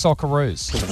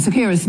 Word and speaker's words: Socceroos: they don't know how to say Socceroos. Well, they have a listen to how she pronounced Socceroos. – Two goals in extra time took it to penalties, but then Sakira's missed two spot kicks. – Sakira's. – It's not that hard.